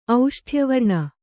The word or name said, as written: ಔಷ್ಠ್ಯವರ್ಣ